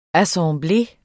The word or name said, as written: assemblé